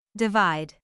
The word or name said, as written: divide